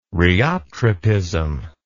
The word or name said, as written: rheotropism